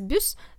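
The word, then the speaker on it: buss: То есть на английском оно пишется бас с одной S, а в норвежском оно пишется ну, оно читается бюс,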